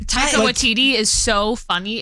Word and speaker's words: Taika Waititi: And Rita Ora and her husband, Takiti. – I love him. – No, sorry. Taika Waititi. – Mm-hmm. – Taika Waititi. Sure.